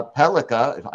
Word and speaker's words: Pellikka: And our host of Locked On St. Louis Blues, Haley Simon, going with Axel Sandin uh, Pellikka.